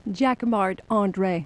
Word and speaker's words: Jacquemart-André: Or no, you say it like André. A Frenchman. – Jacques Marc. Of course, André. Because I'm French. So Jacques Marc André.